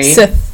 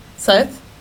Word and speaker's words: scythe: Am I saying that right? I'm gonna be very Sith. – Sith. Sith. Scythe. Is it a scythe? Scythe. It is a scythe.